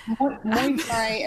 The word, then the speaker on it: Muay Thai: It's Muay Thai and then Mai Thai is the cocktail.